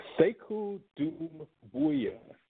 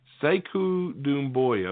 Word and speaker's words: Sekou Doumbouya: Tell me if I'm wrong here. Sekou Doumbouya. – Sekou Doumbouya. – Okay. Sekou Doumbouya. Okay, thank you for that.